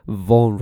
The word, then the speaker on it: Vaughan: And the actual pronunciation of Bowen Radio is Vaughan Radio, right? So you pronounce Vaughan Radio. But because no one pronounces like that, and, you know, for marketing purposes, they use Bowen Radio. It's better for marketing.